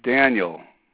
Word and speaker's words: Daniel: All right, it's Daniel, D-A-N-I-E-L. My last name is spelled B-O-B-R-O-W.